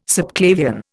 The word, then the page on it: subclavian: Indian